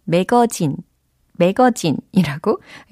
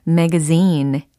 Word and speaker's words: magazine: 잡지, 잡지, 이것을 영어로 매거진, 매거진이라고 예, 또박또박 발음을 하는 경우도 있는데요. 그것을 이제 영어식으로 좀 더 연습을 해보자라는 취지예요.